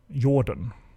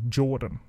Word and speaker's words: Jordan: And we don't have hard J's in the Swedish language, so I've always, by default, went with Jordan rather than Jordan, right?